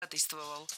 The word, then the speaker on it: ходатайствовал: ходатайствовал. Ударение в этом слове падает на первую «а» в суффиксе «атой».